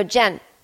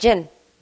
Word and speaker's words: Jen: Very often my name is spelled wrong. Because I go, Jen. I go, what's your name? I go, Jen. I mean, I probably say it so fast. Jin. Sometimes people think I'm saying Jin.